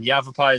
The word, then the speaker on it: Yavapai: Yavapai is exactly the right pronunciation for that.